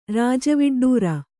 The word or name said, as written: ರಾಜವಿಡ್ಡೂರ